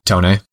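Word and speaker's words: Toney: How do you say his last name? – Tony. – Okay. Oh, it is straightforward.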